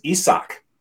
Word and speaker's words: Isaac: His name is Isak. – Isak Paredes. And I only know this from the broadcast. – Okay. – So I've always called him Isaac.